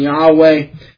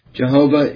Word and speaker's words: Yahweh: in verse 13, God reveals his name to be Yahweh, corresponding to the four Hebrew consonants YHWH. And I should note that everyone doesn't agree with the pronunciation Yahweh. Jehovah is also an alternative that is favored by some.